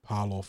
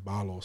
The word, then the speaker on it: Paulo: We obviously had our own junior, Big Junes, tell us how to pronounce his surname correctly recently after being Parlor for so long. It's more of like a soft B and being Barlor.